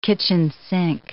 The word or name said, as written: kitchen sink